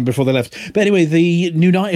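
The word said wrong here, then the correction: Nuyina: is it Nunia? Nunia? Hang on a second. I've got a pronunciation. – Noyena, isn't it? – Noyena, that's the one.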